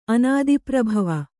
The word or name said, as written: ಅನಾದಿಪ್ರಭವ